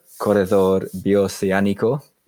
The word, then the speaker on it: Corredor Bioceánico: In Paraguay, we have the Corredor Bioceánico Road. – Good pronunciation. – I try.